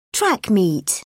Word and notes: track meet: British English

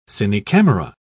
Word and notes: cine-camera: British English

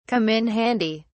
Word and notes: come in handy: British English

